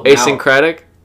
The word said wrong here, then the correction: asynchronous: Is that how you say it? – No, asynchronous.